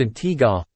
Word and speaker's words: Antigua: Antigua, pronounced Antigua, and Barbuda, Barbuda, is located in the heart of the Caribbean Sea.